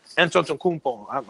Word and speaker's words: Antetokounmpo: People who don't even know how to say Antetokounmpo. They still add the T in there somewhere. – Until Akina, yeah. So I'm like, yeah, it's silent. It's a silent T.